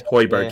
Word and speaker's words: Højbjerg: Yeah, Højbjerg, yeah.